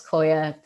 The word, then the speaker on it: Qoya: Koya. So Koya, and that's spelled Q-O-Y-A, and it's a movement practice designed for women.